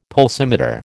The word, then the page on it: pulsimeter: American English